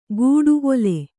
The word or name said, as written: ಒಲೆ